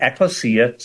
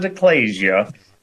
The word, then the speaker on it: ekklesia: What we see in the lukewarm, heretical, false expression of the word church, which is based on a Greek word, ekklesia, some people pronounce it ecclesia,